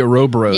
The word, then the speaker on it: Ouroboros: I mean, there was actually a poster for my band Bomb that was the Ouroboros. Is that how you say it? The snake eating its own head. – The Ouroboros.